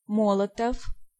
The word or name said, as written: Molotov